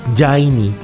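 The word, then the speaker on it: Jani: Tomaba el nombre Johnny de sus abuelos alemanes, quienes escribían su nombre J-A-N-I y lo pronunciaban con Y, Jaini.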